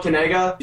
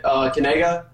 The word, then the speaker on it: Kanega: Oh, so this is how you pronounce it. It's Noah Kanega. You say Kanega like that. – See, that's the key. It's Kanega.